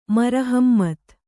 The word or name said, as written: ಮರಹಮ್ಮತ್